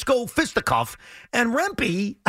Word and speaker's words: Rempe: Yes, Rempe, who's about 8 feet tall.